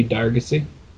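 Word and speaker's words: Dargocey: Jimmy Dargacy. – Okay. All right. Do you want to spell that for the listeners? – Sure. It's JimmyDar. G-O-C-E-Y. – Awesome. And it's Dargacy, not Dargosy.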